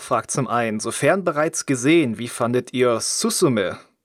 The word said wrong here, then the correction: Suzume: Ja, ich glaube, es wird Suzume ausgesprochen. Also Z ist immer ein sehr weicher Laut.